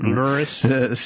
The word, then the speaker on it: Lewis: Lewis.